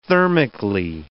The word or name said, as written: thermically